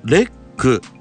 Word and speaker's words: LEC: さあ、まずははい、社名のレックというこの名前の由来から教えていただけますか。 – はい、レックは LEC でレックと読みます。